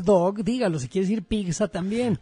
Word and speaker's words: pizza: Pero en Guadalajara no dicen pizza. – Pero el Canelo dice, a ver, vele a reclamar. – Canelo, se dice pizza con T. Es doble Z en italiano, se pronuncia T.